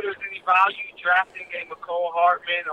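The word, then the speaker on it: Mecole Hardman: You mean McCole Hardman? – Yeah, there you go. – Wow. – Sorry. – You butchered his name, dog. – My bad. – He said, man, Marcel Hateman.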